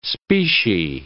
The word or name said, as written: specie